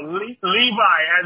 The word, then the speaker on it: Levi: My name is Levi. – How do you spell that? L-I-V-A? Leva? – No, Levi, as in blue jeans.